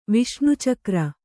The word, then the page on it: ವಿಷ್ಣುಚಕ್ರ: Viṣṇu cakra